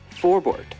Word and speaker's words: Forbort: Do you want them to have, you know, no fear in going after guys like Forbot? So, I mean, I still don't even know how to say the guy's name. Forbot, Robot, whatever the hell his name is. – Winnipeg Jets defenseman, Derek Forbort.